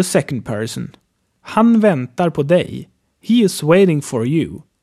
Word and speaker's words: dig: This is written like D-I-G but pronounced G-E-J.